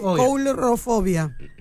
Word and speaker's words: coulrofobia: Mirá cómo se dice. Coulrofobia. Coulrofobia.